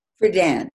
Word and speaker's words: Friedan: The show features five women, Phyllis Schlafly, Gloria Steinem, Betty, is it Friedan? – Friedan, Shirley yeah.